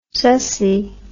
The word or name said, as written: sussy